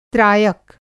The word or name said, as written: त्रायक